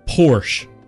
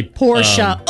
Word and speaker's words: Porsche: Porsche. A lot of people say Porsche.